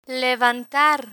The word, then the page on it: levantar: Spanish